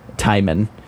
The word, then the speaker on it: Tijmen: His name is Timon Tio, which is spelled T-I-J-M-E-N, Timon.